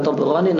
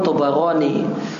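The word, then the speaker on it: Thabarani: Tabarani itu baknya di fathah bukan di sukun. Cara baca yang benar bukan Tabarani namun Tabarani fil Mu'jam Al-Kabir dari Ibnu Mas'ud.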